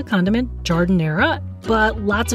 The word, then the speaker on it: Giardiniera: It's Giardiniera. Giardiniera, which could be also the name for a female gardener.